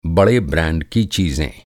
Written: ब्रांड

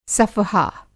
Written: सफ़हा

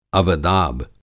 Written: अवदाब

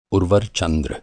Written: उर्वर

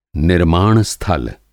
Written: निर्माण-स्थाल

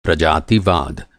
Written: प्रजातिवाद

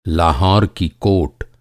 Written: कोट

